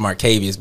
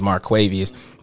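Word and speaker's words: Marquavious: I actually do go to places, but most of it, the majority of it, is actually on my TikTok, which is Marquavius. It's actually pronounced Marquavius, but everybody see it as Marquavius. But it's Marquavius Smith 5 on TikTok.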